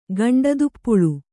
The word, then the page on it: ಗಂಡದುಪ್ಪುಳು: kannada